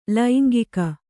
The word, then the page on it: ಲಂಗಿಗ: langiga